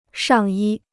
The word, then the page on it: 上衣: shàng yī